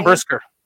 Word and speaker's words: Brisker: And was it Basker? I always say his name wrong. Number nine. I'd like to see them get some experience. This is what we want because they need. – Jaquan Brisker.